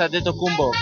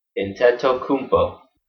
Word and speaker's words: Antetokounmpo: Is that close? – Antetokounmpo. – Antetokounmpo. Okay. – Hi, Milwaukee. I'm Giannis Antetokounmpo. Can you say my name? – Antetokounmpo. Got it.